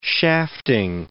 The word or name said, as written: shafting